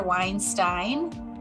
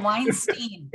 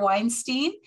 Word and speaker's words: Weinstein: He is Dr. Peter Weinstein. Did I say it right? – No. – Oh, Weinstein. Gosh, I knew I was going to get that wrong.